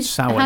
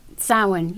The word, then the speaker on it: Samhain: Samhain is pronounced, as like with all of Gaelic, it's pronounced differently the way it's spelled. – It's S-O-W-I-N-N. – It's S-A-M. – S-A-M. – M. H-A-I-N.